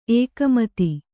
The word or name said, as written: ಎಗುಮತಿ